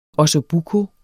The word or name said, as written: ossobuco